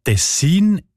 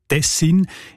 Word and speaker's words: Tessin: Die Variante mit der Betonung auf den ersten Silbe und mit kurzem «i», also Tessin, ist seltener.